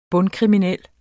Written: bund-

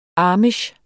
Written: amish

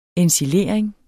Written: ensilering

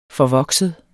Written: forvokset

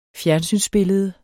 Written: fjernsynsbillede

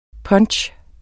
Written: punch

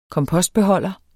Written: kompostbeholder

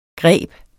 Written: greb

